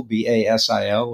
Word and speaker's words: Basil: Basil, B A S I L, or Basil as I pronounce it, like the spice.